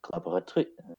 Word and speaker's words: collaboratrice: collaboratrices.